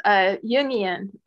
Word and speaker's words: Jungian: It's Jungian. It's like, so it's Carl Jung's ideas, which are complicated and far reaching.